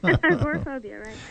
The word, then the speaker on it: agoraphobia: And it's been proven that he had this agoraphobia. Is that how you say it? – Mm-hmm. – Yeah, okay. I got it right.